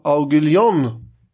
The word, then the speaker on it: Aiguillon: Det är först när hertigen av Aiguillon... – Perfekt uttal. – Tack, jag är mycket nöjd.